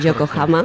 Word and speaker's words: Yokohama: en Yokohama. ¿Vale? Se dice así. – Sí. Es correcto. Es Yokohama. Correcto.